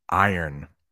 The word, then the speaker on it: iron: Let's talk about pronouncing that word, iron. That iron is also steaming. And if you look at the screen, I have it written out for you. Like I earn. So like money.